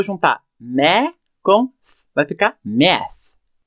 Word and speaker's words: math: E essa primeira parte que a gente vai trabalhar, math, M-A-T-H. Percebe que tem um TH no final, M-A-T-H. Essa é aquela pronúncia que nós já fizemos menções algumas vezes, que ela é somente um sopro.